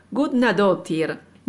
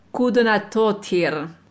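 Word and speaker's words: Guðnadóttir: La primera es la islandesa Hildur Gudnadóttir. No se pronuncia así, la pronunciación más aproximada a lo que sería en islandés es algo así como Gudnadóttir.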